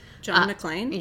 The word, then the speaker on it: John McClane: John McClane? – Yes.